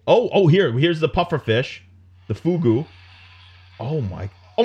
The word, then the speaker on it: fugu: Oh, is that how you say it in Japanese? Pufferfish still, or is it fugu. – Another? Fugu. – Fugu, yes.